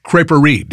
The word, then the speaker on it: crêperie: But you also have a traditional French crêperie. Did I say that right? – Yeah, you said it right.